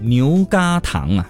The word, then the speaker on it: 牛轧糖: 正确的规范读音应该是这个叫嘎嘎啊，牛轧糖啊，是吗？